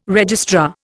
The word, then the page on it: registrar: Indian